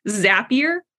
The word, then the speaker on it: Zapier: I'm getting corrections in the chat about how I'm pronouncing Zapier. Is it Zapier? – It's Zapier.